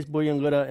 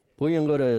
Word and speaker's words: Buyungurra: You can use Buyungara in this sentence. Ngāra gāmaru Buyungara yūgānga. I cooked a turtle yesterday. Buyungara is spelled B-U-Y-U-N-G-U-R-R-A. Can you say Buyungara, turtle?